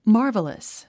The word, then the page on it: marvelous: mάː r v ə ləs; マーバラス